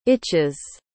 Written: itches

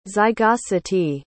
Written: zygosity

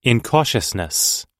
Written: incautiousness

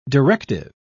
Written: directive

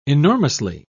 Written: enormously

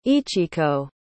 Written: ichiko

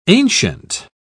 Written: ancient

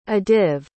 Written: adiv

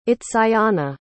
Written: itzayana

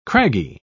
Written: craggy

